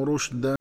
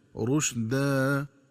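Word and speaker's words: رُشْدًا: وكذلك ينبغي التنبه للنطق الصحيح لكلمة رشدا، فتقرأ بضم الراء وتسكين الشين هكذا رشدا، ومن الخطأ قراءتها بفتح الراء والشين هكذا رشد.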